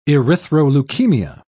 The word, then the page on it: erythroleukemia: British English